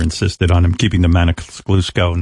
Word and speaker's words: Maniscalco: Maniscalco. How do you say his name, Gary? – Maniscalco. – Maniscalco.